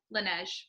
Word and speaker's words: Laneige: Laneige.